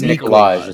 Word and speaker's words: Nikolaj: You're not saying it right. It's Nikolaj. It's not Nikolaj.